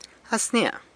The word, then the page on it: As nia: sanlucasquiavini